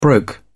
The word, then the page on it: broke: British English